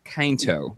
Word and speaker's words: Quinto: I think it's Zachary Quinto. – No, pretty sure it's... The emphasis is on the I.